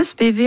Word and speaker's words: Spivey: Det Spivey, ja, og jeg var jo på en lengre research-tur I USA og intervjuet folk, Og da ble det uttalt Spivey. Ja, så Bruce Iglauer I Alligator Records, for eksempel, sier Spivey, Spivey Records. Ja. På Bluesarkivet I Mississippi sa de også Spivey.